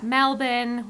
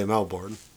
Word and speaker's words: Melbourne: We've got Melbourne. We've got Brisbane. All of them are spelled with R's, but nobody pronounces the R's.